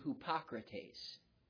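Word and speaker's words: hypokrates (ὑποκριτής): The Greek word hypocrisy is the word hypokrates. That's the word in Greek. That's how you would say it. That's how you would pronounce it.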